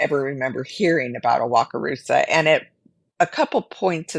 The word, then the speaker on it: Wakarusa: Why don't we at first take a look at the location, which it was Wakarusa, did I say that correctly, Indiana? – Yep.